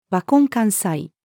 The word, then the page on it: 和魂漢才: female